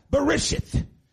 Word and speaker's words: Bereshith: pronounced as Bereshith in their native tongue.